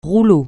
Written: rouleau

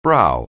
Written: brow

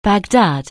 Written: Baghdad

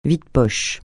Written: vide-poches

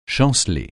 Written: chanceler

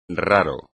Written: raro

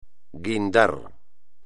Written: guindar

